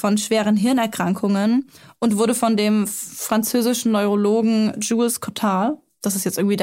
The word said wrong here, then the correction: Jules: Ich glaube, er heißt Jules. – Och Mann, okay, dann war es doch nicht der einzige französische Name, den ich aussprechen kann. Jules Cotard. War das richtig? Okay, wundervoll.